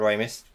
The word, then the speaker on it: Ramis: I thought it was Ramis. – Is it Ramis? I've heard it pronounced both. – Oh, okay. I've heard Americans call him Harold Ramis. – We'll go with Ramis then, because he's American.